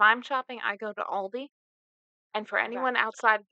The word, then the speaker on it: Aldi: But I'm aware that it's pronounced differently in other countries. But yes. in the U.S., it is pronounced Aldi. – Ah. – So, because I've seen we have people from Germany, and mm-hmm. I believe they pronounce it differently yeah.